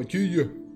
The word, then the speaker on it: Eugeo: Yuyo es como Eugeo, se llama. Y los japoneses lo pronuncian como Yuyo. Yuyo.